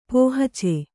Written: ಪೋಹಚೆ

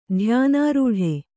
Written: ಧ್ಯಾನಾರೂಢೆ

